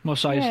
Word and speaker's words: Mos Eisley: Mos Eisley. – Mos Eisley, yeah.